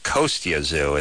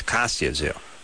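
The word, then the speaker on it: Kostya Tszyu: He doesn't pronounce it Kostya Zoo. It's Kostya Zoo.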